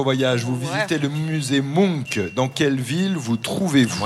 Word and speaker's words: Munch: Ça s'écrit M-U-N-C-H et je crois qu'on prononce Munch.